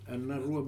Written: Anarua

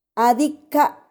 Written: 2adika

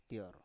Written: tior

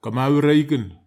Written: kamawra iken

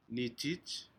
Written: nijij

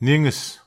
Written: niges

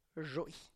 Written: zhoy

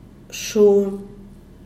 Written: shun

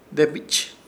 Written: Debich

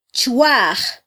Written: chwaj

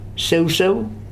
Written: seuseu

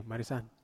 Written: marisan

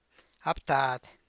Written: a btáad

